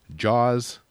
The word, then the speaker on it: Jauz: Grime, Robin Schulz, Slander, Yellow Claw, Zen Freeman, Black Coffee, Cheat Codes, Galantis, Jamie Jones, Jaws, that's spelled J-A-U-Z.